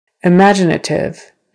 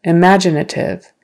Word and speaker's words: imaginative: Imaginative. Imaginative. Now it's on the second syllable.